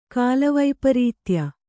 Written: ಕಾಲವೈಪರೀತ್ಯ

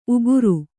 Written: ಉಗುರ್

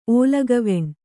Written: ಓಲಗವೆಣ್

